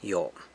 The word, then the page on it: Yo: San Lucas Quiaviní